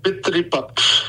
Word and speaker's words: Pitripaksh: Teach me, how you pronounce that? – Pitripak. P-I-T-R-I-P-A-K-S-H.